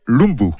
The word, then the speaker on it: Lumbye: Il s'appelait Hans Christian Lumbye et on prononce Lumbye.